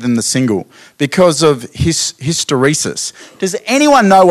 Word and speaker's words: hysteresis: That's how you meant to say the word, hysteresis.